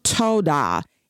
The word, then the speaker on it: towdah: The next word I want to talk about is pronounced toda. It's spelled T-O-W-D-A-H.